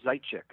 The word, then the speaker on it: Zaitchik: That is, yeah, that's very close. I, uh, I guess Zaitchik.